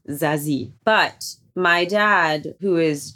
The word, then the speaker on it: Zazie: Zazie, I've been practicing all morning. I've been saying it wrong for years. Did I do that right? Zazie. – Zazie, yeah.